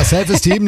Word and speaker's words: Self Esteem: The Offspring, Self Eastern. – Ja, die Aussprache ist ein bisschen... – Team is Team. – Ja. Ja, ja, ist richtig. – Ja, Self Esteem nehmen wir auch.